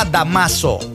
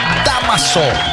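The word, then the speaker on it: Dámaso: No, él no se llama Damaso, él es con acento en la primera sílaba, Damaso.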